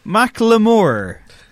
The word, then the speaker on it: Macklemore: It's my boy, Macklemore, as I believe is the correct pronunciation.